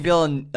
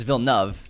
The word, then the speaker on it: Villeneuve: Preston, Anthony was having trouble with saying just Villeneuve. Yeah. He was adding an extra thing in there. And like after two recordings, I'm like, but it's Villeneuve. Villeneuve. Two syllables.